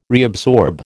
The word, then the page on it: reabsorb: American English